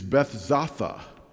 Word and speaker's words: Bethzatha: John tells us it was called Bethesda. That's the popular pronunciation of this word. An alternative and possibly more likely pronunciation is Bethzatha.